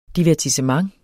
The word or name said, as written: DIVERTISSEMENT